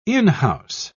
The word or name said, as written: in-house